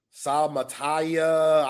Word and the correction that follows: Suamataya: I botched the hell out of that. My bad, bro. – Kingsley Suamataya. – Suamataya. There we go. That sounds better.